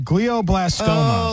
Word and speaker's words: glioblastoma: Glioblastoma.